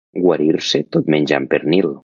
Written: menjant

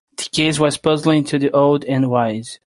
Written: puzzling

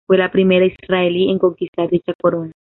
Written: conquistar

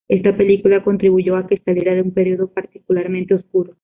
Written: particularmente

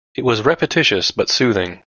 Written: repetitious

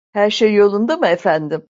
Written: efendim

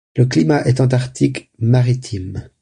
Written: maritime

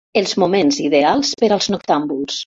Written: ideals

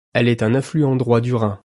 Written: affluent

